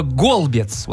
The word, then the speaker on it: голбец: Михаил пишет, боюсь ошибиться, но вроде на Среднем и Северном Урале есть слово «голбец». Вот именно такое ударение он поставил, и это погреб.